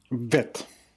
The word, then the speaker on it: Vit: Is it Vitt or Vitt? – Vitt. – Okay, it is Vitt.